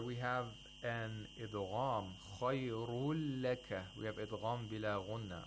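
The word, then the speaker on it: khayrul laka (خير لك): So the nun sakina of the tanween is not pronounced. Instead, you have to pronounce the lam with tashdeed. Khayrul laka. The noon actually merges into the lam. It's like you want to pronounce the noon, but you don't pronounce the noon. Instead, you pronounce one lam.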